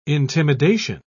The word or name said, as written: intimidation